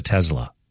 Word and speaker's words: Tesla: Of course, he's got Tesla, or as he pronounces it, he kind of hits the S like a Z. He calls it Tesla.